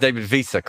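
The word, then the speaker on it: David Wiese: Have I pronounced David Visa correctly? – Yes, you have.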